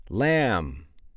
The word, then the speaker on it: lamb: You don't always have to accept the receipt, but that's how you spell it, R-E-C-E-I-P-T. What about the B? Lamb. Yeah, lamb. There's the silent B there at the end.